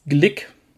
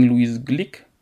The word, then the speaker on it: Glück: Der Gedichtband Averno, der jüngst mit dem Literaturnobelpreis ausgezeichneten Lyrikerin Louise Glück, geschrieben wie Glück, aber Amerikaner können ja eigentlich kein Ü aussprechen, deswegen Louise Glück.